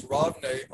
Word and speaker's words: Ravne: And we can see now so we're talking about is it the Ravi tunnel, mate? Is that how you say it? – It's Ravne, R-A-V-N-E.